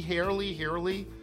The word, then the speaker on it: Haerle: From Wade H-A-E-R-L-E, Harley, pronounced Harley, Queenstown, Maryland.